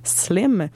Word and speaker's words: SLM: Et ensuite, on va aller entendre Hershey Kiss de Naomi, nouveau single pour Naomi en featuring avec la rappeuse montréalaise et anglophone SLM qui se prononce Slim.